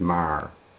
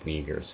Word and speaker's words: Meagher: So I pronounce it phonetically. That is how it looks, and it looks like Meagher.